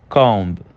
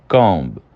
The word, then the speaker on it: comb: E a última aí do silent B, nós temos comb. Comb. O comb, ele já tem uma entonação um pouquinho mais forte do B, mas ainda podemos considerar ele silenciado.